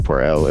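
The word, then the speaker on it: Pour elle: I think it's just Poor L. It's French. – Poor L. – It means for her.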